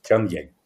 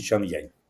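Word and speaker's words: Changan: Волга, безусловно, является в чистом виде китайским автомобилем Чан-Янь, если я правильно транскрибирую это название. Я перед нашим эфиром специально проверил. Кажется, так. Чан-Янь. Пишется в английской транскрипции Чанган, но по-китайски Чан-Янь.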